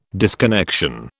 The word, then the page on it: disconnection: British English